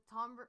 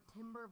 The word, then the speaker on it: timbre: I just thought like the timbre or timber, whatever the fuck you pronounce that. – Timbre. – T-I-M-B-R-E, whatever.